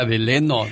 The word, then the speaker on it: Delano: Dice, saludos para la cuadrilla de Fidel que andamos trabajando en Deleno, California. Pero dice Delano. – De, es que ah. así se escribe, pero se, pronuncia Deleno.